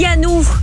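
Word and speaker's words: Pjanoo: Si je te dis Pianou. Alors ça s'écrit Bjanou. Hein. – Ah, c'est Bjanou, donc c'est du suédois ça.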